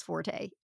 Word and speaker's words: forte: I've only ever heard people pronounce that word as forte. All right. So I still say it's forte.